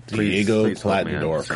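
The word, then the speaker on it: Diego Klattenhoff: Diego. How do you say? – Klatendorf. – Diego Klatendorf. – Klatendorf.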